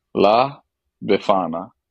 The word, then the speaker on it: La Befana: La Befana.